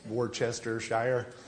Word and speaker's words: Worcestershire: You know, like Worcestershire sauce, it's Worcestershire.